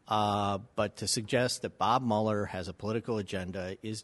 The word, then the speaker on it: Mueller: Bob Mueller is how it's pronounced.